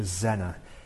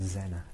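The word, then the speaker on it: Zaehner: So I'm going to read you some bits from his letter to Dr. Zenner. It's Z-A-E-H-N-E-R. I think it's pronounced Zenner. Zenner.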